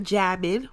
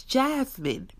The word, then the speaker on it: Jasmine: My name is Jasmine. And if you're going to say Jasmine, I need you to say it correctly. It's not Jasmine without an S. It's Jasmine.